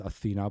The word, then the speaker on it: Athena: Je prononce ça à l'anglaise, Athena, pour faire genre, tu as vu, mais ça s'écrit Athena, comme la déesse grecque.